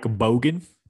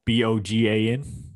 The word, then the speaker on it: Bogan: Mike Bogan, Bogan. – B-O-G-A-N.